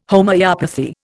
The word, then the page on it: Homoeopathy: Indian